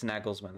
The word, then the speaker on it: Nagelsmann: Okay, it's Nagelsmann. That's how you say his name, according to this pronunciation website I found.